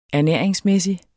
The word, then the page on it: ernæringsmæssig: æɐ̯ˈnεˀɐ̯eŋsˌmεˀsi; -ˌmεsi